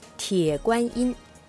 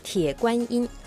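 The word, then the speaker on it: 铁观音: はい、中国語で発音すると鉄观音、鉄观音。 – はい、鉄観音、鉄观音ですね。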